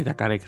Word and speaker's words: Yacaré: Creo que Yacaré lo están pronunciando, aunque no tiene el acento.